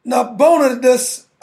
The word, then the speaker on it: Nabonidus: Technically, his father, Nabonidus, I believe is how you pronounce his name, is the king.